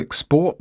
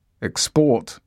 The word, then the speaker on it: export: They want to export their goods to France. Export is a verb. Stress on the last syllable. Tourism is an invisible export. Export here is a noun. Stress on the first syllable.